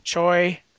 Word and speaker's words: Choi: Ryan, Cho, I'm gonna pronounce his name wrong. Choi, I think that's Choi. Choi. – Yeah.